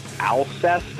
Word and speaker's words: Alcest: And then as far as newer stuff, I really dig this band called, I think you pronounce it Alcest. It's A-L-C-E-S-T.